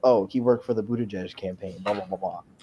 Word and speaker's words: Buttigieg: Here's a pronunciation. This is what they use for his campaign. That's how it's pronounced. – Buttigieg.